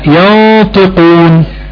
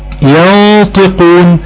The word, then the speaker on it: ينطقون: زي ينطقون، عندها نون ساكنة وراء حرف الطاء، الطاء من حروف الاخفاء، حكم النون اخفاء حقيقي، مع غنة مقدارها حركتين، يبقى عندنا حرف الغنة النون هيبقى فيه كمان التفخيم عشان وراء حرف الطاء، يبقى ينطقون ينطقون. طيب